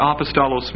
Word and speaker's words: apostolos: Now, the word apostle actually is a transliteration from the Greek word apostolos. Apostolos. And that's spelled A-P-O-S-T-O-L-O-S.